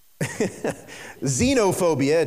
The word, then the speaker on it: xenophobia: There's one called xenophobia. It's spelled with an X, X-E-N-O, but it's pronounced xenophobia.